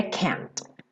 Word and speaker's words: can't: A pronúncia mais americana seria can't. Can't be broken. A mesma coisa aqui. Can't be. Você nem escuta o T.